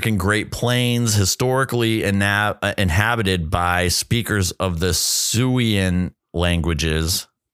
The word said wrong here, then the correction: Siouan: Siouan. Oh, Siouan. – Sorry. My apologies on the mispronunciation there. Siouan languages.